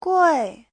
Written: gui4

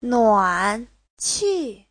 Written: nuan3qi4

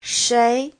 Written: shei2

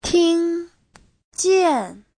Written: ting1jian4

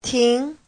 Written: ting2

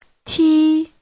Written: ti1

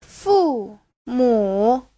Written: fu4mu3

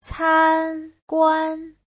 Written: can1guan1

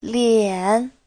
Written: lian3

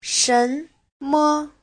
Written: shen2me5

